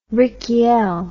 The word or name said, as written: rykiel